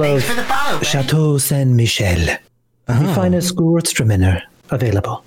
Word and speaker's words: Gewürztraminer: It's pronounced Gewürztraminer. That rhymes with Hertzmawiener.